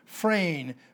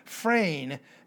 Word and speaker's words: phren: It is the Greek word phronesis, P-H-R-O-N-E-S-I-S in the Greek, phronesis, P-H-R-O-N-E-S-I-S, long E pronounced as an A, phronesis. It comes from the noun phrein, P-H-R-E-N, again, long E pronounced as an A, phrein, and phrein means thinking.